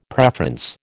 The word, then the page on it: preference: American English